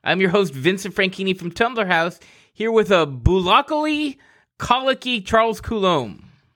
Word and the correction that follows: bucolically: Did I say that right? Bucolically colicky Charles Coulomb. There we go.